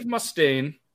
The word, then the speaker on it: Mustaine: Before forming Megadeth, Dave Mustaine, sorry, that, that's how you say it, right? – Yep.